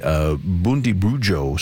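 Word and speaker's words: Bundibugyo: And doctor, if you don't mind, tell us more about what's happening in Congo with the Bundibujo strain of Ebola. I hope I said that correctly. – Yep, you've got it perfectly.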